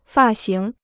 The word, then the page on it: 发型: fà xíng